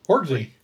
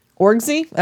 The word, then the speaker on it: Orczy: The Baroness... O-R-C-Z-Y. How do you say that? Anybody got a pitch for how to say that? – Orgzy. – Orgzy?